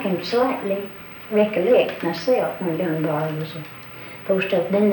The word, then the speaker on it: Dunbar: And so he named it, he said, Dunbar, and it wasn't long till it became D-U-N-B-A-R, Dunbar. Two syllables, but not two words.